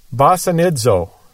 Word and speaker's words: basanizo: The Greek word here is basanizo, spelled B-A-S-A-N-I-Z-O. And again, in Koine Greek, you always pronounce a Z like it has a D in front of it. Basanizo.